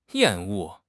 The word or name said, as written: 厌恶